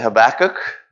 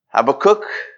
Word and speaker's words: Habakkuk: Some people say Habakkuk. Habakkuk, I'm probably going to say both while I speak. If we were in Brazil, we would say Abacuki, which is even more confusing.